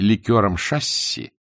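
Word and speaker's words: ликёр шасси: Гидросмесью, гидрашкой или ликером шасси, с ударением на первом слоге, авиаторы называли амортизационную жидкость для тогдашних поршневых самолетов.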